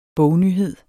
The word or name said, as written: bognyhed